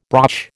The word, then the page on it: broch: American English